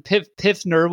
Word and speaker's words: Pfizer: But I've been seeing the vaccines that, like, from Pfizer, which I can never say, how do you pronounce that? – Pfizer. – What did I say? – The Pfizer? P is silent. The P is silent, just like the P in baby.